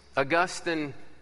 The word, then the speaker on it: Augustine: And let's just get his name right first as we kick it off. Saint Augustine is a place. Augustine is a man.